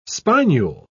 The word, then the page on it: spinule: British English